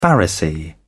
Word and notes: pharisee: British English

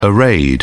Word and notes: arrayed: British English

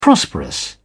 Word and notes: prosperous: British English

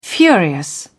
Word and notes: furious: British English

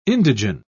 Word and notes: indigen: British English

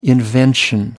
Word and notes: invention: American English

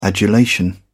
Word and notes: adulation: American English

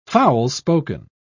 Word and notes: foul-spoken: British English

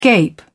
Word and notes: gape: British English

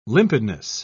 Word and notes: limpidness: British English

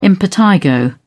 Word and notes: impetigo: British English

